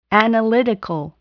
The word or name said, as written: analytical